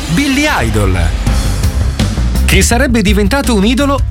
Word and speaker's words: Idol: Idol, idolo. Sostantivo che in inglese si pronuncia esattamente come idol, lavativo.